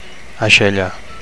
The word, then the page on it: aixella: Catalan